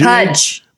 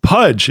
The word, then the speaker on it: Puj: Is it sure. Pooj? P-U-J? – Pudge. – Pudge. It is Pudge. – Like why does baby it look like Pudge, but it is spelled P-U-J.